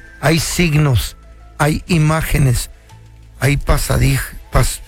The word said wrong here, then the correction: pasadizos: ¿Cómo se dice? Pasadizos.